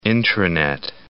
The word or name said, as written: intranet